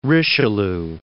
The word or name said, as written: Richelieu